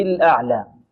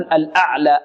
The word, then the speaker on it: الأعلى: هنا الهاء ليس فيها صلة هذا الضمير لأن بعدها ساكن ربه الأعلى العين، نعطيها صفة ماذا؟ التوسط الأعلى، لا نقول الأعلى أعلى أعلى لا، أو الأعلى أعلى، البعض ينطق هكذا يفخم العين الأعلى، هذا خطأ، وربما يفخم معه الهمزة أعلى ربه الأعلى، لا خطأ، الأعلى أ، الهمزة مرقة والعين مرقة ومتوسطة، الأعلى أعلى، مقدار حركتين للف.